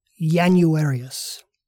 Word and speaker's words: Januarius: This means that the first of our two new months was pronounced as if it started with the letter Y, Januarius.